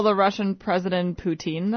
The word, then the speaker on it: Putin: It's Putin, not poutine.